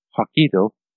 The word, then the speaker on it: Hokkaido: Hokkaido.